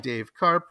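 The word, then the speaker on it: Dave Karpf: It's called The Future Now and Then, and you can find it easily enough just by Googling Dave Karp with an F, silent F at the end, Substack.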